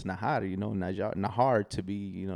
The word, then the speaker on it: Najar: they would say, uh, they would say Najar, which is the proper way to say it. And I'd say, no, it's Nahar because of me and my culture.